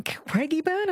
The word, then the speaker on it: Craigieburn: Carol from Craigieburn, am I saying it right, in Melbourne? – Yes, you are.